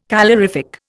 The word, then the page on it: calorific: Indian